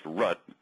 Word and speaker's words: root: the thing right up top is called the root. Only computer people would draw a tree with the root up in the air. And the root, unless it's pronounced rut if you're from certain parts of the country, is written as a dot.